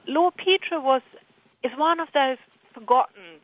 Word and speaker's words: Petre: Is it Peter or Petre? – I think it's Petra, but... – Petra, okay. – You know, one of those things, nobody really knows because names can be pronounced as the family kind of likes. – Sure. – But I call him Lord Petra.